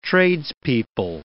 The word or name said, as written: tradespeople